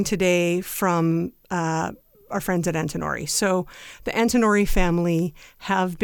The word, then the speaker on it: Antinori: It's a name even I recognize to Antinori.